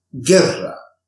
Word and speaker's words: guerra: The combinations G-U-E and G-U-I, the U in between the G and the E is a silent U and is there to produce a softer sound for the letter G. Let's see some examples. Guerra, amague, Miguel, merengue, guitarra, guía. In the combinations of G-U-E and G-U-I,